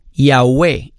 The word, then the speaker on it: Yahweh: En hebreo, ellos lo escribían con 4 letras, YHWH, el tetragramatón, que últimamente le pusieron unas vocales para poder pronunciar estas 4 letras, YHWH, para que sonara como Yahweh.